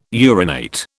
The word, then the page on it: urinate: British English